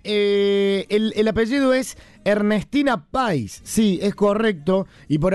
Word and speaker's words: Pais: estos autocorrectores con los que por ahí redactamos y escribimos, hay que estar bastante atentos porque no es con tilde en la I, es con tilde, o por lo menos la acentuación del apellido de Ernestina, la periodista fallecida, es Ernestina Pais. Esa es la pronunciación correcta del mismo.